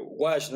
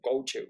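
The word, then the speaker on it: gouache: Wash, ¿no? Como que todos los dibujos que veo que me gustan son de Wash, entonces que, que chingada, o sea, ni siquiera lo pronunciaba bien, güey, yo decía coach,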